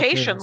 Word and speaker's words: Cation: It's Cation, like staycation.